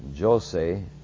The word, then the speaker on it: Jose: I think it's spelled Jose, J-O-S-E, or Jose, as the Spaniards would say.